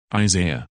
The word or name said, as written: Isaiah